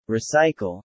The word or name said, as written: Recycle